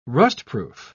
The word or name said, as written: rustproof